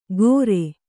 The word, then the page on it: ಕೊರೆ: Kannada